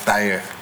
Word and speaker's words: Tahir: We just said Tahir with the American accent, but the dialect is Tahir.